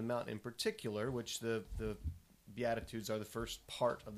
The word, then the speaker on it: Beatitudes: Beatitudes. – Did I say it right? – Yes. – I hope, okay. – Yes. Yes, Beatitudes.